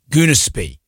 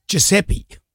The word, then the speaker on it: Giuseppe: But she flew with a guy called Goonespy.